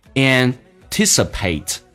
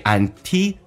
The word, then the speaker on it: anticipate: Anticipate， or you can say anticipate 都可以，英式发音叫 anticipate，美式发音 anticipate。